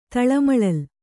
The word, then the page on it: ತಳಮಳಲ್: taḷamaḷal